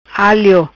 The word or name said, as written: Alio